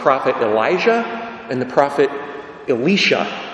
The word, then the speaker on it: Elisha: In Hebrew, you say Eliyah and Elisha, with the emphasis on the last syllable. It's the only way they sound different, but they're two different people, I assure you.